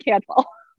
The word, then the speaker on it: Cantwell: It's pretty much, unfortunately, just like it's spelled given my job. It's Lauren Cantwell.